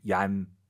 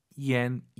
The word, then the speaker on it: 烟: 그리고 정말 많은 초급자분들이 이 YAN을 딱 보시면 얀 떠올라요. 얀 아니죠. Y를 항상 마음속으로 I로 바꾸세요. IAN, EN이잖아요. – 얀. – 얀, 네. 정확한 발음은 yen 이로 시작하는 거예요. yen 이렇게 발음하면 좋을 것 같습니다.